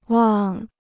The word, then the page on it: 望: wàng